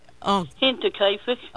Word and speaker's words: Hinterkaifeck: And the latest book you wrote, Terror in the German Countryside, I can't pronounce that word H-I-N-T-E-R. – Hinterkaifeck.